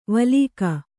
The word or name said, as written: ವಲೀಕ